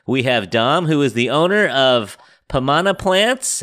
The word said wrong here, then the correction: Pomana Plantas: Am I saying that right? – Pomana Plantas. – Pomana Plantas. Yeah.